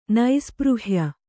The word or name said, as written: ನೈಸ್ಪೃಹ್ಯ